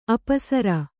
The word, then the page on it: ಅಪಸರ: Kannada